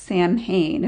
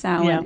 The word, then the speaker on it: Samhain: Well, and you mentioned Samhain, which is spelled, it looks like it's Samhain if people come across the word, but it's pronounced Samhain.